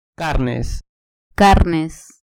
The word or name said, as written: carnes